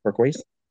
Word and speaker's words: turquoise: The color scheme is that torquoi purple. How do you say turquoise? – Turquoise purple.